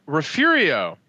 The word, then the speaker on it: Refugio: So, Refurio is actually refugio, but we added an R. It's refurio because reasons.